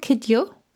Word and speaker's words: Kidjo: Is that what she's called? Kidjo? Is that how you pronounce it? Yes, it is.